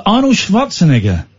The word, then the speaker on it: Schwarzenegger: But Arnold Schwarzenegger, that's how you say it, pops up.